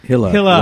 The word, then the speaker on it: Hille: We're going to take another quick break and then talk to Christoph Ille? Manila. – Hilla. Rhymes with Manila.